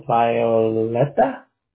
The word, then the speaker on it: Violeta: So V-I-O-L-E-T-A. – Yes. Exactly. – Oh. So Violeta? – Yes. – Gotcha. – Yeah, we pronounce it Violeta.